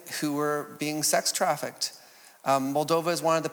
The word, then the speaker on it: Moldova: Moldova is the most impoverished nation in Europe, the highest levels of unemployment.